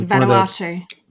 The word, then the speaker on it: Vanuatu: Vanuatu. – Thank you. One of the Pacific Islands, basically, to the list of countries whose nationals require a visa to travel to the UK. For Vanuatu.